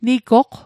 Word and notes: nigoq: Mi'gmaq

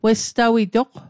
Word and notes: westawitoq: Mi'gmaq

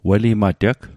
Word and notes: welimateg: Mi'gmaq

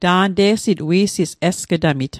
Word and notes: esg'tamit: Mi'gmaq

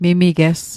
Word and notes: mimiges: Mi'gmaq

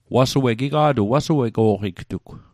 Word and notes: wasuego'q: Mi'gmaq

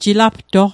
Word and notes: jilaptoq: Mi'gmaq

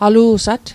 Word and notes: alu'sat: Mi'gmaq

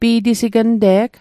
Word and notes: pi'tisignte'g: Mi'gmaq

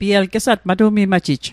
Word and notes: matu'mimajit: Mi'gmaq

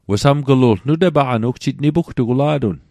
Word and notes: wesamglu'lg: Mi'gmaq

